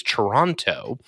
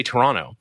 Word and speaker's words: Toronto: Well, this week in the news, I don't know if you heard about this, but a gentleman in Toronto, and that's how Americans say it, is Toronto. – That's true. – We say Toronto. Up in Canada, but Toronto had a Range Rover stolen recently, as will happen.